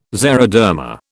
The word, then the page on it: xeroderma: British English